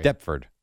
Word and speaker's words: Deptford: How do you say it? – Deptford.